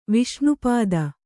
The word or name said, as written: ವಿಷ್ಣುಪದ